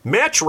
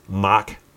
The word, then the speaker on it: Mach: They would pick one up and go, "Oh look, Match Rider." I'm like, "It's Mock Rider. It's Mock M A C H. It's pronounced Mock."